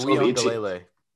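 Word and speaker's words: Uiagalelei: I think it's Weon Delele. – Okay, whatever that was. – It's weird. There's no N in the name, but there's like a N in how you say it. It doesn't make much sense.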